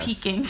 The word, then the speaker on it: Peking: saw him outside of Insomnia Cookie and Pecking Duck. – Yes. – Peking.